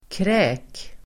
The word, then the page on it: kräk: [krä:k]